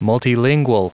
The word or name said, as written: multilingual